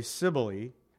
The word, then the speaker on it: Cybele: They pronounce it literally, Cybele, Apollo.